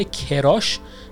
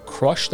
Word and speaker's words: crush: برای قسمت بعدی میخوام که درباره کلمه crush صحبت کنم که توی فارسی هم ازش خیلی زیاد استفاده میشه، یعنی با تلفظ اشتباه کراش خیلی ها اینو استفاده میکنن که crush در واقع تلفظ باید بشه، نباید بگید کراش، که در واقع این تلفظ فارسی این کلمه هست.